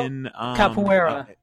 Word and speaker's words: capoeira: Carpor, that thing that started in Brazil, capoeira.